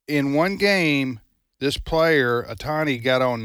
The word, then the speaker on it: Otani: But that Otani, he hit two home runs last night. The Japanese fella. – Yeah. – Did I pronounce his name right? – Okay. You did. – Otani, and then he also got on base.